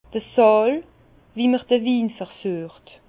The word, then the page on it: d'r Sààl wu m'r de Win versüecht: Bas-Rhin; Herrlisheim